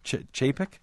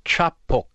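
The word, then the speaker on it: Čapek: I think you pronounce it, Carol, is it Chapek? Chapek? – I think it's Chapok, but I call him Chapek.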